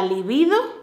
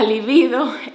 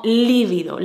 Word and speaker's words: libido: Y probablemente, como es muy parecida al adjetivo líbido, líbida, que se escribe con V, la gente pronuncia libido mal. Y la pronuncian como líbido, la líbido. Y le ponen la tilde. Pero no, es llana, con B, y no lleva tilde.